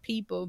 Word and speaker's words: PPL: Okay, now Drom and BJ the Chicago Kid, People, but it's spelled PPL.